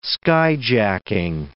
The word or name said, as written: skyjacking